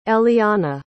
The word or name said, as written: elianna